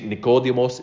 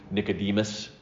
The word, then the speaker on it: Nicodemus: Saint Nicodemus is the Greek pronunciation. It's what I'm used to. Nicodemus is how we would normally say it in the West.